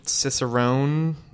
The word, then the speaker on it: Cicerone: And speaking to that, there's the master Cicerone.